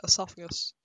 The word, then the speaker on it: esophagus: Esophagus. It's just pronounced like that? – Yeah, esophagus.